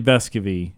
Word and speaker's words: Vescovi: It's just a difficulty how to pronounce it sometimes. Vescovi. – Yeah, it is officially Vescovi.